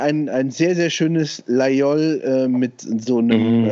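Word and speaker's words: Laguiole: Ich habe auch so ein paar Laiol, das wird, ne, viele sagen Lagiol, es wird aber Laiol ausgesprochen.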